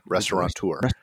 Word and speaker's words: restaurateur: Well, I'll have to admit that on SBTV we said that it said it the same way, restaurateur. And I have a feeling I've never asked my current how do you say it? – Restaurateurs. – Yes. How they pronounce it. But some of my other friends in the past have always pronounced it the incorrect way. – With the N, yeah.